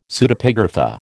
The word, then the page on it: Pseudepigrapha: American English